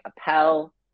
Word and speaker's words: Appel: So you can find me on Instagram at Lucas, 2 underscores, and then my last name, Appel. Some people like to say Apple, but that's not the correct pronunciation.